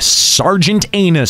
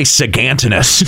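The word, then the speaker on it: Sargatanas: That's not how I pronounce it either. – That's the real pronunciation. – I say Sagantinus.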